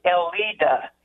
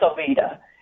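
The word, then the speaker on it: Eleda: And so I do correct people, but it's with a smile and just, it's pronounced Elida, you know, and try to make light of it.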